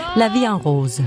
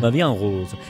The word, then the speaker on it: La Vie en Rose: Som jeg overhovedet ikke udtaler rigtigt. – Nej. – Hvordan skal det siges? – La Vie en Rose. – La Vie en Rose.